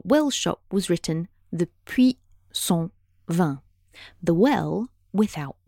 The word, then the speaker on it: Puy: Puy, as in his name, is spelled P U Y, but it sounds exactly like puits, P U I T, which is French for well, as in water well.